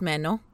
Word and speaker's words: Manon: It's spelled Manon, but it's Menno.